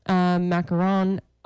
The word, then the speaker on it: macaron: So macaroons have two O's and they're the coconut things. – Sure. – Macaron, it's one O. And you say it more like a French person. – Macaron.